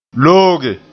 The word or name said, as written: looge